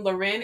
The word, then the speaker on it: Lauren: You can find me on Instagram at Love Evolves, or you can type in my name, Loren. It's spelled Lauren, L-A-U-R-E-N, F Walker. And my Instagram handle is Love Evolves, L-O-V-E-V-O-L-V-Z.